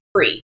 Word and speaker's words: pri: And I find that the word for fruit is, in the Hebrew translation of this verse, is pri. P-apostrophe-R-I. And as soon as I saw that word, I just knew this is it. And it just so happens that it rhymes with CBD.